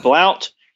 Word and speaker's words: Blount: That's Jeb Blount. My last name is spelled B-L-O-U-N-T. So for all the Yankees, I know you call it Blount, but it's Blount.